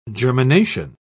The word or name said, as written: germination